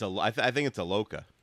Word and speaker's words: Iloka: George Iakoa? Did I say that right? The Bengals safety just got... – I think it's Iloka. I think it's just regular. It's pronounced like it's spelled.